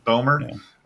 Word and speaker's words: Bomer: I went with maybe a little bit lesser known of an actor, but Matt Bomer, I'm not really sure how to pronounce that last thing. – Bomer.